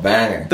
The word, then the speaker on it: Bangor: So I got corrected when I said Bangor. – That's, and they're like, no, it's called Banger. – That's the English way of pronouncing it. But okay. Like, if you're Johnny Cash or anybody else from Maine, then you say Banger.